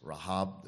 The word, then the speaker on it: Rahab: Again, it's Rahab. There's really no Rahab. American way of pronouncing it.